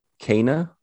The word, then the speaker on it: Kena: Correct me if I mispronounce, because I can't ever get this pronunciation right, but I think it's Kena, Bridge of Spirits.